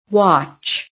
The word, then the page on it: WATCH: American English